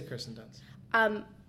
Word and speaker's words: Kirsten Dunst: Am I saying that right? – Kirsten Dunst. I would say Kirsten Dunst.